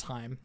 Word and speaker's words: Haim: You've got Alana Haim, I believe it's Haim, that's how you pronounce it.